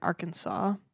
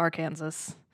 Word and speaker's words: Arkansas: Eventually, the family would settle in Harrison in northern Arkansas, uh, not to be confused with Arkansas.